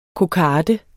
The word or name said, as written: KOKARDER